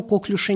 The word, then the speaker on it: коклюш: Значит, я вам напоминаю, что коклюш – слово французское, и ударение вы теперь, будучи знакомы со мной, никогда не сделаете. Никакого коклюша нет. Есть только коклюш с ударением на последнем слоге.